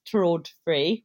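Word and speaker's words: TRÅDFRI: I think it's actually pronounced Trud-Free, which is Swedish, of course, because IKEA is Swedish.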